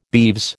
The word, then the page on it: beeves: American English